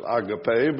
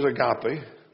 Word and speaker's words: agape: Some people pronounce it agape, but you know what it is, agape.